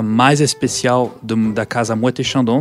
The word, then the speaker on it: Moët et Chandon: Moët et Chandon.